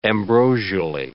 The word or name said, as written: ambrosially